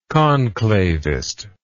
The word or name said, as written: conclavist